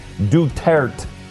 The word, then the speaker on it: Duterte: So it's D-U-T-E-R-T-E. Duterte. It's not Duterte.